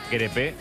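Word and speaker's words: Crépeau: Dice Marquínez que se dice crepe, como un crepe de Nutella, de nocilla.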